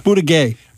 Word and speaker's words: Buttigieg: Buttigieg. – Right. Buttigieg.